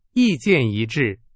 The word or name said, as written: 一致